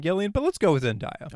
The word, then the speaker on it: Zendaya: But I think Zendaya, is that how you pronounce her name? – I believe it is Zendaya.